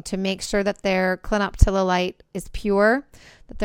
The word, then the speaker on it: clinoptilolite: which come from a zeolite called clinoptilolite.